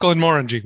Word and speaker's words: Glenmorangie: It's pronounced Glenmorangie.